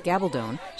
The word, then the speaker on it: Gabaldon: Gabaldon, she says her Spanish surname rhymes with bad to the bone, has a Ph.D.